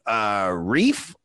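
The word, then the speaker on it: Relf: Keith Reif, R-E-I-F. Yep. Did I say that right? – Yep.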